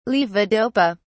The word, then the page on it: levodopa: British English